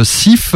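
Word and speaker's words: Syph: On va s'écouter une réédition d'un vieux groupe des tout débuts de la scène punk ouest-allemande, SIF,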